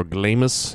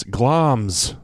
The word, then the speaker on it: Glamis: G-L-A-M-I-S should be pronounced Glamis or Glamis in my mind. – Mm-hmm. – But it's Glamis, apparently.